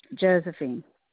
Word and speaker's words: Josephine: It's Josephine Metz, M-E-T-Z. – And the first name? – Josephine. – Could you spell that for me? – It's J-O-S-E-P-H-I-N-E.